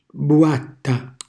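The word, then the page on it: buatta: Calabrian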